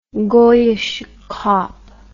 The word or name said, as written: goyische kop